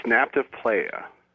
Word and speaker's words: Nabta Playa: It's Nabta Playa.